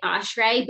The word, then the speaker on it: Oshry: You said it like the proper Jewish pronunciation. – Oh, we just happen to say it O'Shray, but the, you know, the Chai and the ancestors would say O'Shray.